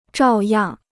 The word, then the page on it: 照样: zhào yàng